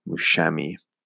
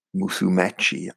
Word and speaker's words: Musumeci: I don't think that's how you pronounce his last name, but I'm going to get it up. I'm really bad at it. Again, I should rehearse these names. Mikey Musumeci, actually, maybe how you pronounced it.